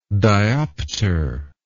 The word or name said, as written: dioptral